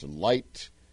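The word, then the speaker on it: Licht: I think it's pronounced Light, L-I-G-H-T, or C-T, L-I-C-T-H-T, L-I-C-H-T. I haven't bothered to learn it, and should get around to it, I know.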